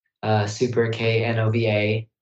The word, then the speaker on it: SuperKnova: It's SuperKnova, spelled with a silent K in the middle, SuperKnova, SuperKnova Music, same for all the social medias at SuperKnova Music.